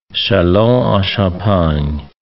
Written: Chalons-en-Champagne

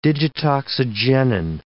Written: digitoxigenin

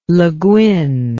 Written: leguin

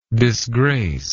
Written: disgracer

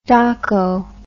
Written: doco

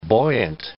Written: buoyant

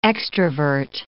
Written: extravert

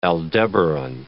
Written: Aldebaran

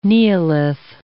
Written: neolith

